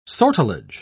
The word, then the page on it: sortilege: British English